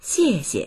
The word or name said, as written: xie4xie